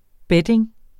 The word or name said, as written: betting